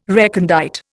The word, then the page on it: recondite: Indian